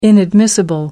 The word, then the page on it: inadmissible: American English